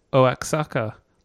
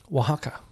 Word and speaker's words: Oaxaca: And it comes with a QR code, which says Oaxaca. – Oaxaca. – Oaxaca. At the bottom of it. – Because remember, X is pronounced with a H.